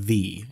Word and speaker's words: ye: Same thing with like when we see ye old choppy, mm-hmm. that was actually pronounced the. It wasn't pronounced ye.